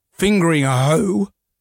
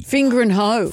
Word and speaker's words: Fingringhoe: Spare a thought for the people over in Essex at Fingering Hoe. That's the name of the town, Fingering Hoe. – How do you spell it? Start again. – Fingringhoe.